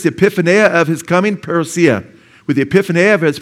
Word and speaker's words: parousia: He says concerning Christ's coming, and that the Greek word there is parousia. Some pronounce it parousia, okay?